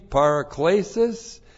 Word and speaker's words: paraklesis: Once again, n plus the dative is by means of, dative of means, paraklesis. This is the noun from the verb parakaleo. That's P-A-R-A-K-L, long E-S-I-S, paraklesis. Paraklesis,